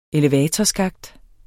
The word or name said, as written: elevatorskakt